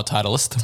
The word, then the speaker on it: Titleist: Oh, Titleist.